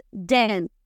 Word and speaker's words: than: Pero el qué comparativo es den. Then. ¿Cómo se escribe? Escriban. T-H-A-N.